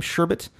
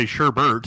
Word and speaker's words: sherbet: A lot of people mispronounce it and say sherbert. – That's what I'm saying. – There is no R.